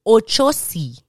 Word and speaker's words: Ochosi: Ochosi. Ochosi. Spanish is very much the same. Ochosi. It's just the OSI is pronounced a little bit differently. Ochosi.